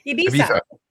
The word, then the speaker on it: Ibiza: Look, say, how do you say it? – Ibiza.